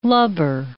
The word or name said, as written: lubber